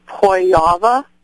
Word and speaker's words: Pojawa: Okay, my last name is pronounced Pojawa. The J is said like a Y, and the W is said like a V. And it's a Polish last name.